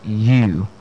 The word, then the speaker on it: ewe: Yes, and this word, E-W-E. Interestingly, this word people pronounce as Ewe. And I remember one of our senior, the WAC exams, saw the word, and a lot of students could not pronounce the word. Yeah, and the word is simply pronounced as U. Just the same way you pronounce your Y-O-U. Yes, you. That's the way it's pronounced. And the second word is the word Y-A-C-H-T.